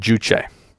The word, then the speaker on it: Juche: Juche. Now, Juche is spelled J-U-C-H-E. Sometimes you'll hear it pronounced Juche.